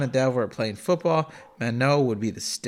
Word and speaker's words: Manon: Yeah, M-A-N-O-N, right? – It's M-A-N-O-N. – Yeah. Well, I don't know. That's how you pronounce it. – Oh, French. I thought the last N was silent. – It is. Manon.